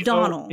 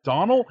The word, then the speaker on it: Domhnall: Donald? – Yeah. – D-O-M-H-N-A-L-L. Donald, get the fuck out of here, you fucking... You don't pronounce the M and the N. They're the M and the H.